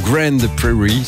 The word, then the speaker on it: Grand Prairie: On est au Nokia Theater de Grand Prairie, ça se trouve pas en Belgique, c'est au Texas, mais ça se prononce Grand ou Grand Prairie si vous préférez.